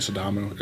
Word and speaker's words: Sidamo: And all of my favorite coffees are like African, like Yirgacheffe and Sidamo. Is that how you say it? – Yeah.